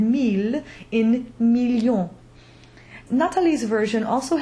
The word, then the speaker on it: mille: We talked a lot about the ILLE being the J glide or the yod, but we also mentioned the exceptions, mille, tranquille, ville, where the L is pronounced.